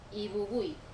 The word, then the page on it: vuvu: Wamesa